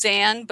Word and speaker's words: Zan: So if you join the DragonCon Newbies group on Facebook, which is DragonCon Newbies, my name is Zan Bowden. It's Z-A-N, so Z as in zebra, A-N.